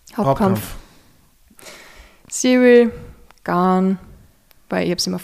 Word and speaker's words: Gane: Entweder Gane oder Gain. Gain war immer die erste Version, bis ich dann, also bei den ersten Kämpfen. Dann glaube ich Gane, aber man sagt einfach nur Garn.